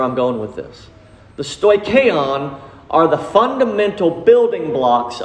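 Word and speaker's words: Stoicheon (στοιχεῖον): Said it wrong. Stoicheon. Stoicheon, it's a word that, again, it's not too familiar with to us, but it's a concept that we can gather.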